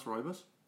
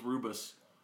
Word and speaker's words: rooibos: Is that how you pronounce rooibos? – Yeah. – Mm-hmm. – What'd you think it was? – I thought it was rubus. – Rubus? – Oh. – That's incorrect.